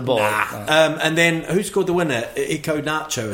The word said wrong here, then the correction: Iheanacho: I think it's pronounced Iheanacho.